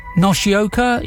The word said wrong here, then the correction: Yoshioka: is that right? Say it again. – Yoshioka. – Yoshioka, okay, thank you.